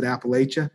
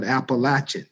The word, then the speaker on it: Appalachia: For the people who are from New York, that's how they pronounce it, Appalachia. But the people who are from the South, they call it Appalachian. So it's two pronunciations there.